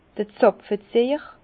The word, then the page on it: d'r Zapfeziehjer: Bas-Rhin; Herrlisheim